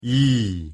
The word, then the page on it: 이: Korean